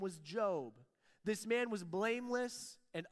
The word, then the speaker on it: Job: It's spelled J-O-B, Job, but it's Hebrew, not English, so it's Job.